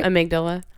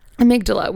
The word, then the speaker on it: amygdala: He's clearly oversimplifying, but Fallon says the orbital cortex put a brake on another part of the brain called the amygdala. Did I say that right? – Amygdala. – Amygdala, which is involved with aggression and appetites.